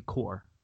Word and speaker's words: Corps: The S is silent and it comes out to just be core.